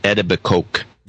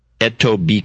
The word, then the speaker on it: Etobicoke: Which I always mispronounce. He's in Ontario, but the town is Etobicoke. – What's his name? I stepped on you. – Etobicoke.